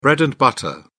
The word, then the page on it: bread-and-butter: British English